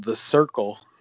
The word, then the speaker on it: Cyrkle: I bought this lovely piece of wax from a band called The Circle, which is spelled C-Y-R-K-L-E.